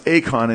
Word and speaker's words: eikon: It's pronounced akon in Greek, if you, E-I-K-O-N.